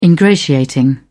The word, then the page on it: ingratiating: British English